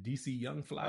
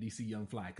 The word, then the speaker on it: DC Young Fly: And those dudes, so is it DC Young Fly? – Uh-huh.